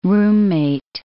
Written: roommate

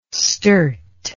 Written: sturt

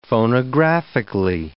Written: phonographically

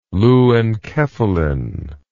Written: leu-enkephalin

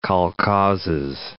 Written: kolkhozes

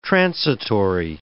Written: transitory

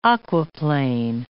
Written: aquaplane

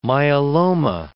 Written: myeloma

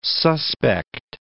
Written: suspect

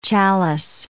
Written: chalice

